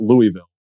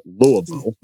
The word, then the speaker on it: Louisville: And about now 16, 17 years ago, when I was going to wrestling school in Louisville, Kentucky, of all places, and it's not Louisville, they pronounce it Louisville for all my locals there, Louisville, Kentucky.